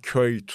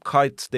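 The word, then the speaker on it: Kuyt: Dirk Kite aslında Köyt falan diye okunuyor. Ama 15 yıldır adam Kite denilirken burada da Kite denebilir.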